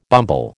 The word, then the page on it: bumble: American English